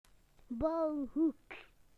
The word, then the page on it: building corner: British English